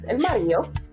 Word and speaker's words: mario: Viene de la palabra marido, con D, pero como estás en República Dominicana, te comes esa D y dices el Mario.